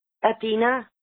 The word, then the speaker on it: Athena: A-T-H-E-N-A. – A-T-H-A-N-A. Athena. How do you pronounce it? – Athena.